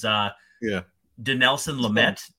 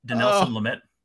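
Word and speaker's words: Dinelson Lamet: So the 3-1 game, this one's going to be a little less painful because DeNelson Lamet. Did I say that right? DeNelson Lamet. – Yes, but oh man, don't that name.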